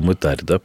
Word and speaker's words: мытарь: Кстати говоря, мы произносим слово «мытарь» с ударением на первый слог, а вы, я заметила, на второй.